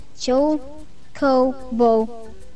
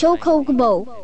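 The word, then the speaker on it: Chocobo: You shouldn't pronounce it with those pauses, though. – Well, I can't, otherwise. – So that was just Choe Ko Bo. – An idiot. Choe Ko Bo. – Oh! – As much as I, yeah, that's how you pronounce it.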